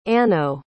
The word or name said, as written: anno